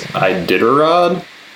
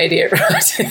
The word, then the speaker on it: Iditarod: I think that's pronounced Iditarod. – Oh, yeah, not Idiot Rod.